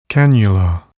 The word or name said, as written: cannula